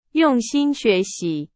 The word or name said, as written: 心